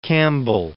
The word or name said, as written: Campbell